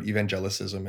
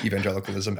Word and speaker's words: evangelicalism: I've had a lot of people ask me about evangelicism, and I just pretend that they said evangelicalism and go from there and then slip in my pronunciation of the word, which is evangelicalism,